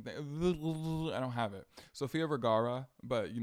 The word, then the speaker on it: Sofia Vergara: And Sofia Vergara, I can't roll my R's, and so I can't really say her name right.